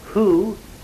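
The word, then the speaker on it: who (הוא): Driver, suggested that the word Yahweh was made up of the pronoun in Hebrew, he, which happens to be pronounced who, and the great exclamation, Yah, which would, according to him, be kind of the thing, hey, that's him.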